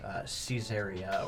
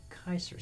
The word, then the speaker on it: Caesar: All C's in Latin are hard C's. A lot of people know that. Like Caesar, that's not how you say Caesar. In Latin, you say Kaiser.